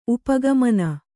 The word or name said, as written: ಉಪಗಮನ